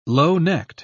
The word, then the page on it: low-necked: British English